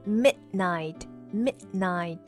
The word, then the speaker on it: midnight: 是读成了 midnight，midnight。